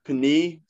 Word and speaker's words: Penei: Penei, like the pasta.